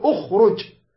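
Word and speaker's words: اخرج: نحسب همزة وصل واحد، الخاء الثاني، الراء الثالث، الراء ننظر حركته، أخرو رو ضمة، إذا شو يسوي؟ نضم الأول، نقول أخرج أخرج، واضح؟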